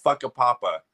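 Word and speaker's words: Whakapapa: I also want to go because I know there's a town north of Wellington called Fuckapapa. And that's how, and I looked it up, I googled it, that's how it's pronounced, right? – Yeah, that's correct. Yes.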